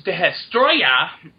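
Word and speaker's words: Destoroyah: I mean, there's Godzilla movie where, like, I mean, the find Godzilla versus Destroya, as it's pronounced, not Destroya. Destroya. Destroya.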